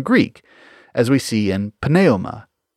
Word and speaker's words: pneuma: Over time, English dropped the F, just as we don't pronounce the P in pneuma.